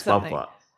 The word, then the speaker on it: Papua: Yes, Papua. Yeah, that's the correct pronunciation.